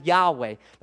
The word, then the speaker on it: Yahweh: Most people pronounce it as Yahweh.